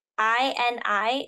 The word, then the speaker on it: INI: Um, night two had ATEEZ I N I. Is that pronounced INI? I don't know. – Yes, they're a Japanese INI.